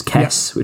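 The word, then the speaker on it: Kes: One is obviously Kez, which he pronounces Kess, which I thought was quite fun.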